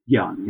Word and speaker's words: gyan: pronunciation of the gyan, is it? – Gyan, yes, that's gyan. Very good.